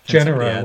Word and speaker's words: Genera: Genera would be how we would mostly say it.